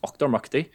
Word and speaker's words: Auchtermuchty: It's spelled A-U-C-H-T-E-R-M-U-C-H-T-Y, pronounced Ochtermuchty, or just Muchty, if you will.